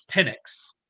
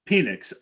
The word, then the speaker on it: Penix: I believe it's Penix. – Yeah, Penix.